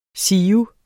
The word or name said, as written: siv